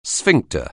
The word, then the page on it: sphincter: anglais